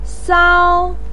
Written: sao1

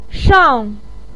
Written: shang4

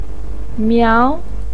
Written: miao2

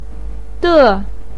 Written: de4

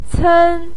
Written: cen1

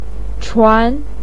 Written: chuan2

